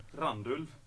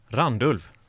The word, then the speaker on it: Randulv: Randulv. Randulv.